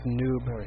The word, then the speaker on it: noob: I only get angry when you pronounce it nub instead of noob.